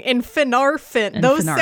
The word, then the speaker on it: Finarfin: No, but you even got the emphasis right, because the emphasis goes on the second to last syllable. So it's Fingolfin, not Fingolfin. It's Fingolfin and Finarfin.